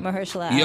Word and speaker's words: Mahershala: Mahershala.